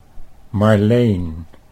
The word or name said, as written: Marleen